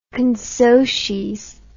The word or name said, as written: consocies